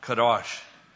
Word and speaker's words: Kadosh: I believe it's pronounced Kadosh. Pardon? – Yeah. – Oh, yeah. Okay. Kadosh.